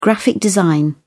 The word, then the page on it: graphic design: British English